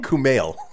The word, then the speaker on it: Kumail: I butchered his name too, right? – Kumail Nanjiani. – Kumali. How do you say his name? – Kumail. – Kumail? – Yeah. – Kumail. – Like you. That is Kumail.